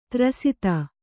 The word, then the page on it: ದುರಾಸದ: Kannada